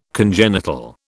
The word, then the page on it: congenital: British English